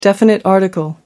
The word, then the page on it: definite article: American English